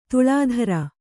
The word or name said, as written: ದಿಲ್ದಾರ